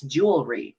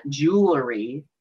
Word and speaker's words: jewelry: It's the word jewel, J-E-W-E-L-R-Y. It's jewelry. It's not jewelry.